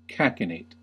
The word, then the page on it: cachinnate: American English